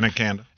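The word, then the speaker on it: Abanikanda: Abani Kanda, I believe is how you pronounce his last name, was able to set them up. – Yeah, Abani Kanda.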